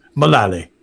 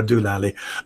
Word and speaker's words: Mullally: And in the last episode, they read out from the Bishop of London, Sarah, I must get her name right because I, Mullally, that's it. I'm afraid, God forgive me, I keep on wanting to call her Sarah Dulally.